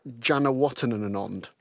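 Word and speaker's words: Janewattananond: Jaz Janawatanand. – Oh, there's an extra bit. You just say one more than you think you should, than you think's acceptable.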